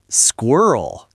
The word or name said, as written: squirrel